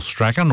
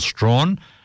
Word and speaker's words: Strachan: And before that, an excellent track from Michael Strachan, or Michael Strawn, wherever you are in the world, you pronounce it differently.